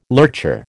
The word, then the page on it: lurcher: American English